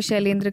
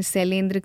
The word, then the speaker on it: शैलेंद्र: कह रहे हैं कि सुनना तो अच्छा लगा, पर बीबीसी संवाददाता प्रदीप कुमार, कई बार उन्होंने शैलेंद्र का उच्चारण शैलेंद्र किया है, और भी जगहों पर श का उच्चारण स हुआ है।